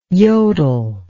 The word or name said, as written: yodle